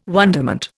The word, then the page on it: wonderment: Indian